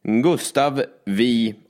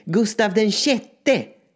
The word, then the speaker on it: Gustav VI: Det uttalas inte vi, det uttalas sex. – Nu förstår jag inte. – Sex. – Ja, för all del, kanske senare. – Nej. Gustav den sjätte.